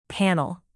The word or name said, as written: panel